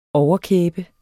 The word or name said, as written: overkæbe